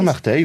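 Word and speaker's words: Marteilhe: Les galériens, et notamment l'expérience de Jean, alors je sais pas si je prononce bien, Marteil. – Jean Marteil, oui. Oui, tout à fait, qui est donc...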